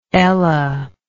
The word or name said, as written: Ella